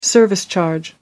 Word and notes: service charge: American English

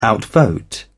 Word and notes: outvote: British English

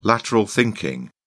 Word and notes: lateral thinking: British English

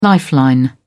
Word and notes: lifeline: British English

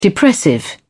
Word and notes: depressive: British English

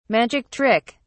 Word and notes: magic trick: British English

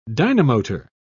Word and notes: dynamotor: British English

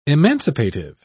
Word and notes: emancipative: British English

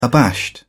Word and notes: abashed: British English

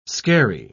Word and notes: scarey: British English

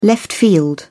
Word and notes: left field: British English

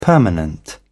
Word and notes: permanent: British English

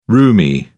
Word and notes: rheumy: British English

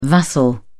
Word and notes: vassal: British English

